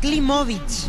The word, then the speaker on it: Klimovic: Así es, como si fuera con B corta, Klimovic.